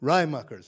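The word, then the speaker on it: Raaijmakers: A particular model that instantiates this encoding variability idea is by Jeroen Rademakers. Does anyone know if I'm saying his name right? I always ask people that. – It's Rademakers, thank you.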